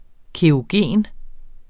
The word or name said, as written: kerogen